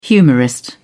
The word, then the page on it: humorist: British English